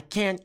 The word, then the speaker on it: can't: Veja que o T não soa. Eu não falo, I can't go. Eu não falo, I can't. Eu falo, I can't. Cadê o T? Não tem, eu não pronuncio, ok? Então, I can't go. I can't go. O que dá a ideia do T é a pausa.